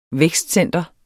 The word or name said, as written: vækstcenter